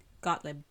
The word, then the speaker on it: Gottlieb: Got, Gottlieb. – Well, yes, Gottlieb. It's just the lib part.